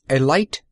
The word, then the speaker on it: Elite: Chug Scarrett was, and is, the proprietor and sole owner of the Elite Garage, and he pronounced it with a long I.